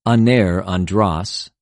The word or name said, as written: ἀνηρ,ἀνδρος